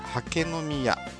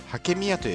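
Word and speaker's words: 八景水谷: ね 8 つのね、はい、景色の景ですねで水の谷と書いて 4 文字で刷毛宮刷毛宮と呼びます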